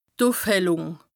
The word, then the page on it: d' Ùfhellùng: Bas-Rhin; Strasbourg